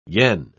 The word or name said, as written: yen